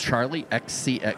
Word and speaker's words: Charlie XCX: No, you said it right. – Do you pronounce it XX? – No. – XCX. Like Chock-X? – Charlie XCX. You're adorable, Scott.